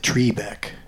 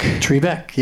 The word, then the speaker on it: Trebek: Plus also, the announcer, Johnny, pronounced it Trebek. – Trebek, yeah. – Why do Jeff... – And nobody says it that way.